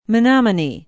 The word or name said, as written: menomonie